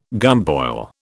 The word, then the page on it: gumboil: British English